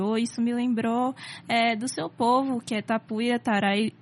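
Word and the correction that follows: Tapuia Tarairiú: Me corrija, que eu não sei pronunciar. Você pode dizer? – É Tapuia, Tarairiu.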